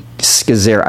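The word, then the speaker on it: Scazzero: But like a few years ago, and you're preaching at New Life, and the founding pastor, Pete Scazzera, I feel like I never say his name right, even though he's been on the podcast. Yep. Did I say it right? – That's correct, yep.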